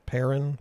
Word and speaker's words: Perron: Is that how you say it? Perron? – Perron. – Perron. – I said Perron.